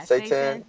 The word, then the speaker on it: seitan: I don't know how you pronounce it right, but it's seitan. – Yeah, seitan.